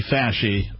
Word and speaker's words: Fauci: No, it's Anthony Fasci. That's the correct pronunciation.